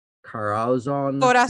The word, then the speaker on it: Corazon: Corazon. – Corazon. How do you say it? Carazon? – Corazon. Amurao. I just saw her picture, actually. Hold on, let me find it. Corazon Amurao. – Amurao. Thank you.